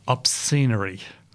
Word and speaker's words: Obscenery: And the track you're going to hear is Obscenery. Yes, that's how it's spelt. That's how it's pronounced.